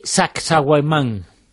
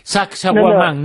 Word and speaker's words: Sacsayhuamán: tiene que ver con el llamado templo de Sacsayhuamán.